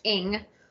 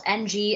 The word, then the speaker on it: Ng: So, as I mentioned, this is the third of Celeste Ng. It's spelt NG, it's pronounced Ng.